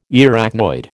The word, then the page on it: Arachnoid: American English